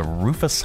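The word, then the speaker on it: Rufous: Rufus Hummingbird, is that how you pronounce it? – That's how's it spelled? – R-U-F-O-U-S. A Rufus Hummingbird. – Sounds like it.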